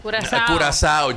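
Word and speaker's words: Curaçao: Óyeme, se escribe Curacao, pero se lee Curazao.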